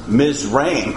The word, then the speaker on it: Mizraim: Here's how you pronounce Egypt in Hebrew. It's Mizraim.